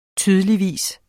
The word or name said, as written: tydeligvis